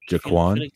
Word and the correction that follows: Joaquin: Joaquin Phoenix.